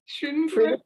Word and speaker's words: Schönbrunn: Schönbrunn Palace? – Schönbrunn. – Schönbrunn Palace.